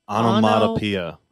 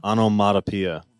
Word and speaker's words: onomatopoeia: Technically, we're all pronouncing it wrong. – Okay, can you pronounce it correctly? – Onomatopoeia. – Onomatopoeia.